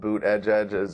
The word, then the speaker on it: Buttigieg: If you're going to pronounce your name Buttigieg, like actually spell it Boot Edge Edge.